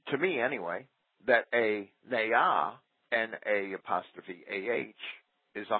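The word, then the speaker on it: na'ah: These words are both pronounced ne'ah, N-A apostrophe A-H.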